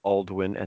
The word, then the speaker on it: Alduin: No, I mean, Alduin. Yes, and we are saying that right. It is Alduin at this point.